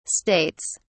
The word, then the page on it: states: British English